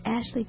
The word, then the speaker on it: Astley: A-S-T-L-E-Y. I get my letters and words all mixed up. Let me spell it again. My husband said I thought it was Astley Parish because that's what I always said. But it's Astley. A-S-T-L-E-Y. That beautiful church still stands in the village of Astley in Worcestershire, England.